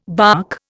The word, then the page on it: barque: Indian